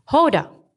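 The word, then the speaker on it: Houda: The nickname that stuck the most was Yoda, since it rhymed a lot with my real name's pronunciation, Hoda. My parents chose to spell my name with a U that is silent when pronounced. H-O-U-D-A, a grammatical choice shaped by the French colonialism that Lebanon underwent prior to its independence in 1943.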